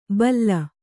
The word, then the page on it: ಬಲ್ಲ: balla